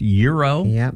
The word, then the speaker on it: gyro: Kim Cunningham Smith says, a word I always say wrong is gyro, because it's pronounced euro properly.